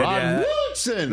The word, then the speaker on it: Rod Woodson: For guests, we've got Ric Flair and Rod Woodson. Is that the way I got to say his name? – I think it's pretty good. – Rod Woodson. – That's pretty good.